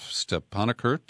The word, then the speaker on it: Stepanakert: And they take us to the town of Askaran, which is north of Stepanakert.